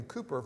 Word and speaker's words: Cowper: It's spelled C-O-W-P-E-R, but William Cooper from England.